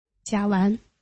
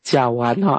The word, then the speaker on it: 甲烷: OK，好，那接下来呢是这个字怎么读啊？甲什么？我读不出来。 – 甲烷。 – 甲烷哈，好，那甲烷呢、啊？你在这里呢？泽文斯基的后面有一个甲烷哈，那甲烷呢？